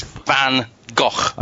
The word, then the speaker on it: Van Gogh: It's Van Gogh. – Okay, there's another... – Not as the Doctor pronounced it, Van Gogh, which just means he's from London, which is really fucking annoying.